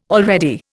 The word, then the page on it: already: Indian